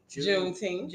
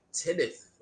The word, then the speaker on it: Juneteenth: Juneteenth. – Oh, I was saying like Tenneth for the longest. No. That's how it's spelled. – It's Juneteenth. – I was putting the syllables in the wrong spot.